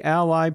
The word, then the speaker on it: a11y: Now Shiny developers can easily leverage this great toolkit with the Shiny Ally package, and that last part is spelled A11Y, authored by R user and musician Ewan Henderson.